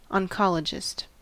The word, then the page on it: oncologist: American English